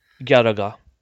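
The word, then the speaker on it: Garaga (ギャラガ): You want to pronounce them like you would the ones in Spanish, and do your best not to stress any of the syllables. Stressing syllables are saved for emotional expression. For example, let's take the name of the sequel to Galaxian, which we'll get to Galaxian here in a moment. You can say Garaga.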